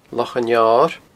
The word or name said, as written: Loch an Fheoir